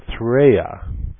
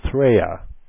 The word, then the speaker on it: Threya: So to do that, I'm going to make up a hypothetical planet, and I'm going to call it Threya. That's Earth backwards. Threa. I've kind of mixed the letters up a little bit, but I pronounce it Threya.